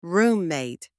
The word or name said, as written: roommate